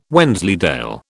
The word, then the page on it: Wensleydale: British English